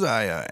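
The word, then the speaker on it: Iya: My name is spelled I-Y-A. I just wanted you to know in case everyone spells it wrong, because they always spell it E-Y-E-Y-A. – I spelled it A-I-Y-A.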